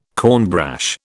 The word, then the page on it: cornbrash: British English